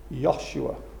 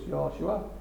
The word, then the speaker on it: Yahshua: They don't pronounce it in the same way you do, like Josh. They pronounce it Yah, Yahshua. So it'd be Yah.